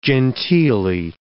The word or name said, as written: genteelly